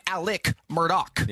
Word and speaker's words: Alex Murdaugh: It's spelled Alex Murdaugh. But when you watch the docuseries, everyone says Alec Murdaugh.